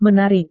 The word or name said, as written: menarik